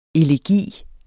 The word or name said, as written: elegi